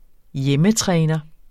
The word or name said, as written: hjemmetræner